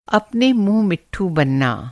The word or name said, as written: मिट्ठू